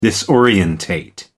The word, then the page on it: disorientate: American English